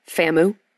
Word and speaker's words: FAMU: And UNC with that start, you feel like they can play both against FAMU. I just like to say it like it rhymes with Shamu, okay? – Can y'all help us out?